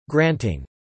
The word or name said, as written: granting